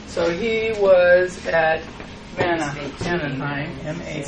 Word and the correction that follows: Mahanaim: Spell it for me, Grace. – Mahanaim.